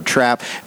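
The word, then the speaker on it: Trappe: So when you say Hurlock or East Newmarket or Preston or Trap, the speed is silent, by the way, in Trap, Easton, Cambridge, any of these places, they have a unique character, and they've got a wonderful character.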